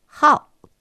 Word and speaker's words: 号: この 5 という字は、ハオと発音します。